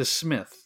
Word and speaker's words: Psmith: Woodhouse, Leave It to Smith. The P is silent. So listeners, if you are writing these down, Leave It to Smith is spelled P-S-M-I-T-H.